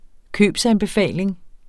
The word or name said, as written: købsanbefaling